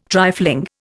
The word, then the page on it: trifling: Indian